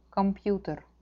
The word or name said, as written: kompjuter